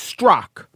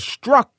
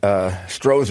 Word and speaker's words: Strzok: Does have a Z in his last name that is doing even less than Eric Trump does, but it is pronounced Strzok. Peter Strzok. Could be a little bit of a struck if you want to strike it as struck, but it's mostly a Strzok.